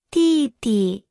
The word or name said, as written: 弟弟